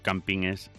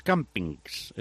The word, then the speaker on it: campines: Mira, antes lo hablábamos en la primera parte del programa con Ángel García, me refiero a lo de los campines, que por cierto no es campings, es campines, que me cuesta aceptarlo, pero se dice así, entonces yo lo digo así, campines de El Folgoso y Peña Agullón, en Sanabria.